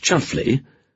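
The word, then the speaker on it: Chumsfanleigh: So Tiffany's friend, Roland de Chums Fanley, pronounced Chuffley,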